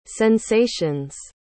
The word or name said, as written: sensations